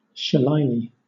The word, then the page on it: shillelagh: British English